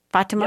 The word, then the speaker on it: Fatima: So whatever country you're from, for you to see, you know, think about this, you know, Fatima, am I saying her name right? Fatima? – Yeah, that's right.